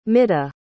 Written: midha

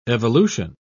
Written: evolution

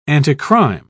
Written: anticrime

